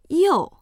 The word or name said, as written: you4